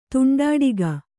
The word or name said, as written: ತುಂಡಾಡಿಗ